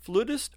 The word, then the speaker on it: flutist: Is the correct pronunciation for what you do with the orchestra flutist or flautist? – Both are correct. There's even a third, flautist.